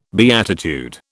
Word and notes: beatitude: British English

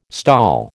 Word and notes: STOL: American English

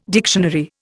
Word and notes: dictionary: Indian

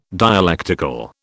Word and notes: dialectical: British English

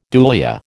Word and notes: dulia: American English

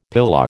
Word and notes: pilau: American English

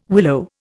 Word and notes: willow: Indian

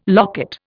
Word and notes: locket: Indian

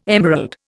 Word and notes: emerald: Indian

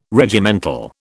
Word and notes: Regimental: British English

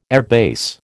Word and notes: airbase: American English

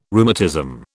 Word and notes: rheumatism: British English

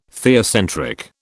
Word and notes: theocentric: British English

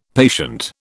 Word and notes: patient: British English

